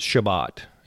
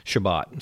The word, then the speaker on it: Shabbat: but it's Shabbat, where we get Sabbath. The English pronunciation of it is, but the Hebrew word is Shabbat.